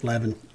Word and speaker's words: Leaven: Levin, like unleavened bread or leaven? – Yeah.